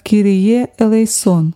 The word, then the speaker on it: Кирие элейсон: Молитва «Господи, помилуй» на греческом языке произносится как «Кирие элейсон».